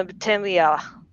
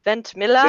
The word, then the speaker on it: Ventimiglia: Milo Ventimiglia. – Sylvester Stallone's kid in Rocky. – That guy. How do you say his last name? Ventimiglia? Ventimiglia? – There it is.